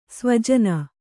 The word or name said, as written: ಸ್ವಜನ